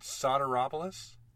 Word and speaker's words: Sotiropoulos: Is it Sotiropoulos? – You got it.